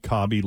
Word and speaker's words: Khaby: Know if I'm pronouncing, Cobby? – Is K-H-A-B-Y, Cobby Lame.